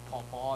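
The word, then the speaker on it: Pau Pau: If you want to look it up, it's spelled P-A-U space P-A-U. It's like Paw Paw.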